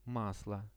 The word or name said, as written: maslo